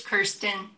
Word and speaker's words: Kirsten: It's Kirsten.